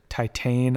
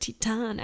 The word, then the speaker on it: Titane: Yeah, I'll also just say we called it Titane up until I just looked up the French pronunciation, which is more like Titane.